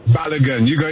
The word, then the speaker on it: Balogun: Did I say that right, or am I messing up that name? – Balogun. You got it right, Myron.